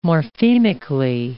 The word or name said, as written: morphemically